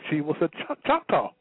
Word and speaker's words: Choctaw: Found out she was a Chickatow, if I'm pronouncing the word right. – Right, Choctaw. Yeah. – Choctaw.